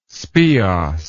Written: speos

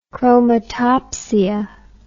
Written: chromatopsia

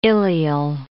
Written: ileal

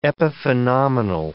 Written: epiphenomenal

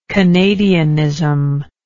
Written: canadianism

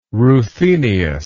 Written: ruthenious

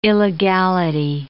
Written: illegality

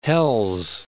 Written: Hells Canyon

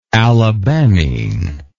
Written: alabamine

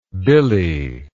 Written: billie

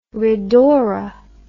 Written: ridaura

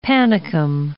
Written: panicum

